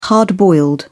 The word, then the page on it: hard-boiled: British English